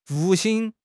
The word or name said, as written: 复兴